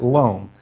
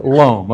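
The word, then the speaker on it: Lomé: Loam. Lome, the only thing that's spelled in the English language that's spelled Lome is the seaport of Lome on the coast of Togo, okay? And it's Lome. It's Lome. And so it doesn't even sound right, so don't try that.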